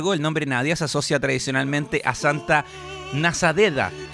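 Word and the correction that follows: Nadezhda: que es Santa... ¿Lo dije bien o no? Santa Nade... Ah, no, es Santa Nadezda.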